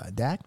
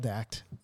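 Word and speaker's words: Dach: Dak, is that how you pronounce his last name? – I think so. If not, I'm a terrible friend. – Lyle Dak. – It's D-A-C-H.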